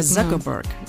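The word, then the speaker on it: Zuckerberg: Συγγνώμη, Ζάκαμπερκ ή Ζούκαμπερκ. Γράφεται Τσούκερμπερκ, αλλά μάλλον διαβάζεται Ζάκαμπερκ.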